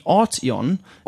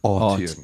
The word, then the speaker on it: Arteon: And talking of news and reviews, you were privileged enough to hop into a new VW called the Arteon. – Arteon. Yes. – And the name Arteon, if I can, the emphasis is put on the first syllable, is derived from two...